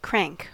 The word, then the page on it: crank: American English